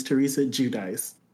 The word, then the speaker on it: Giudice: Her name is Teresa Giudice.